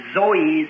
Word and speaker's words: Zoe: is pronounced Zoe, Z-O-E, Zoe life.